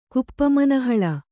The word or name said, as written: ಕುಪ್ಪಮನಹಳ